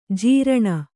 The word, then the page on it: ಝರಣಿ: jharaṇi